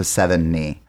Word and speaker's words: Sevigny: Although I think one interview, she said it's like the number seven and then the, on your leg, your knee. So seven knee.